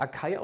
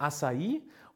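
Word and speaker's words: açaí: Wird nicht Acai ausgesprochen, wird Acai oder Acerola-Pulver beispielsweise.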